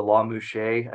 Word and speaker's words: Lamusga: So under the shared custody situation, the court does what's called a La Mouche analysis. – Okay. – It's a famous case in California. It's spelled L-A-M-U-S-G-A.